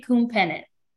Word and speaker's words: Khounphinith: Bree Khounphinith.